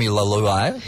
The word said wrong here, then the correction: Luai: No. – Doesn't feel like it was done correctly. – Jerome Luai. – Lovely guy. Correct, yeah.